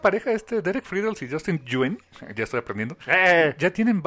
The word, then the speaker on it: Nguyen: Yuen. – No es Yuyen o Yuen, es como Gwen, pero con una G-Y, Yuen. – Yuen.